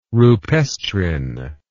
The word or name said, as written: rupestrine